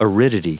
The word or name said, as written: aridity